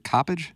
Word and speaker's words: Coppage: Is it Coppage? – Yeah.